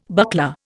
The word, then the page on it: buckler: Indian